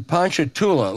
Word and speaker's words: Ponchatoula: This is from Kai in Ponchatoula, Louisiana. Did I pronounce that correctly, James? – You pronounced it exactly correctly.